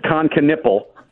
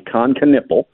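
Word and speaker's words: Kon Knueppel: All right, I'll pronounce it for you. So you got both of them wrong, buddy. I love you. It's Con Knipple. Yes, it sounds like a part on the body. Con Knipple.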